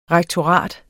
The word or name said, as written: rektorat